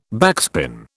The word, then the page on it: backspin: British English